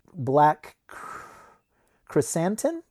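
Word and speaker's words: Black Krrsantan: He releases Black Chrysantin. Did I say it right? – Yep, that's it. Yep.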